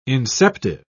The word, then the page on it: inceptive: British English